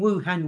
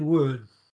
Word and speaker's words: Wuhan: According to the report, which doesn't further specify how he obtained the information, Bloom also states the virus was circulating in Wuhan before it was detected at a local wet market, including the Wuhan seafood market. – You keep calling Wuhan Wuhan. – Wuhan. Wuhan. Sorry, Wuhan. Wuhan.